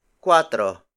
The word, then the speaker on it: kuwatro: The next number is cuatro. Cuatro. Cuatro. Cuatro is spelled as K-U-W-A-T-R-O. It means 4. Cuatro is spelled with a K rather than a C, and W is inserted between U and A.